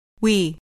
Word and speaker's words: We: We. We. We are. We are from Iran. – دقت کنید که وی نگید. به تلفظش دقت کنید. لبا باید کمی قنچه بشه.